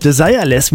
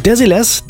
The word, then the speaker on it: Desireless: Die Band Desilets, ja, so spricht man es französisch aus, übrigens nicht englisch, Desireless, man vermuten könnte. Hinter dem Künstlernamen Desilets, da steckt die französische Sängerin Claudie Fritsch-Mentrop.